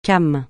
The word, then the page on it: came: francais